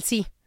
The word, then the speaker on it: sí: Y ve cómo pronuncio el primer, si me invitas, sí voy. Ajá. O sea, hay una diferencia entre uno y otro.